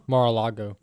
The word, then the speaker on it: Mar-a-Lago: Is it Mar-a-Lago? – Mar-a-Lago. – Yeah. – How do you pronounce it again? – Mar, M-A-R-A-L-A-G-O, Lago.